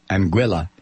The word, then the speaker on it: Anguilla: Anguilla, or you may pronounce it Anguilla.